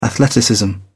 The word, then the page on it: athleticism: British English